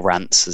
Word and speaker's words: Reims: Because, for example, Reims, a lot of our tours travel through there for or Rants, as the official French pronunciation is.